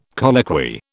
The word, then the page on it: colloquy: British English